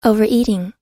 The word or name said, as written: overeating